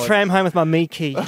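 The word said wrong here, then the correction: myki: In Victoria, we have a card called Mikey, and it's not pronounced Miki. Thank you, for attempting that.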